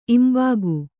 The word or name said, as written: ಇಂಬಾಗು